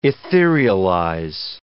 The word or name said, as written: etherealize